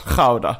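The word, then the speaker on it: Gouda: Och både min pappa och din kompis hävdar bestämt då att goda ost uttalas chauda. Och det gör det ju då på, är det holländska? – Och sen chèvre då.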